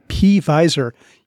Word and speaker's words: P-Visor: We'd like to give a special shout out to our sponsor, Ofarsic by P-Visor. You know, it's not Pfizer, the pharmaceutical company, but visor, like a hat without a lid, but it starts with a P. – Oh, that old silent P.